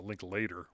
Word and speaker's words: Linklater: And Linklater has, or I guess he pronounces it Linklater, which most Linklaters I know are Linklater, but apparently I've heard him say it's Linklater.